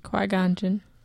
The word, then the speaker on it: Qui-Gon Jinn: Query. – Query. – No, say his name again. – Query. – Full name. – Jin. G-E-N-N? – Qui-Gon Jinn.